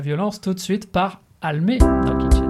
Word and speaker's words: Almé: Almé, comme ça se prononce, avec un « e », c'est important. Donc, absolument.